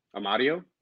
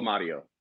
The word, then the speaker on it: Amadio: Amadio. – So you guys signed your third goalie to an extension, and you signed. Say it again. – Michael Amadio.